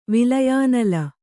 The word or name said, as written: ವಿಲಯಾನಲ